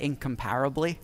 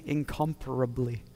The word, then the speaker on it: incomparably: In the UK, we pronounce that incomparably. So it's really weird trying to say it the other way, incomparably.